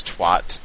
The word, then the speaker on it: twat: I wonder why Americans seem to pronounce twat to rhyme with lot when the rest of the world pronounces it to rhyme with brat.